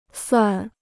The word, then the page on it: 算: suàn